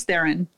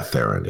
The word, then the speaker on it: Theron: How do I pronounce this? – Charlize Theron. – Yeah, Theron. It's Theron.